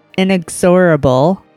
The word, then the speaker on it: Inexorable: And then we're going to go into Galvanizer, a death metal band from Finland, with the song The Inexorable. Did I say that right? – Oh, yeah.